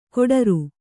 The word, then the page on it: ಗೊಟರು: goṭaru